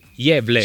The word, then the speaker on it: Gävle: Gavle dovrebbe essere, o Gavle, il paesino. – Gavle. – Si pronuncia Gävle.